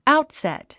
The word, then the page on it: outset: áutsèt; アウトセット